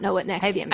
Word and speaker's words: NXIVM: So if you need a Wikipedia wormhole to go down, might I suggest Nexium, which is spelled NXIVM.